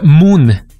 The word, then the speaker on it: Mun: Este proyecto se hace llamar Moon. Yo lo estoy leyendo textual, se escribe M-U-N.